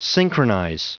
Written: synchronize

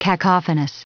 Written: cacophonous

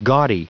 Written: gaudy

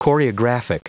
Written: choreographic

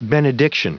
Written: benediction

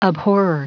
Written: abhorrer

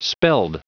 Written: spelled

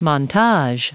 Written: montage

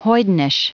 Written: hoydenish

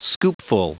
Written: scoopful

